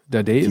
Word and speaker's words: Felix Dadaev: Somebody – how do you say his name? Felix Dadaev? Dadaev? – Yeah, yeah. I think that's good.